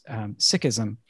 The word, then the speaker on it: Sikhism: He's a Sikh, S-I-K-H, and I really appreciate having conversations with Jay about it because I haven't really known anyone who practices Sikhism. Did I pronounce that right? – Mm-hmm. You could even say Sikhi, which would be the spirit of being a Sikh.